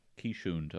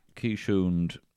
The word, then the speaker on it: Keeshond: A quiche hound. How'd you spell it? K E I S H. Keeshond. I'll show you a picture here. – Oh, thank you. – Keeshond.